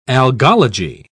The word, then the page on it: algology: British English